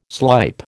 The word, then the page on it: slype: American English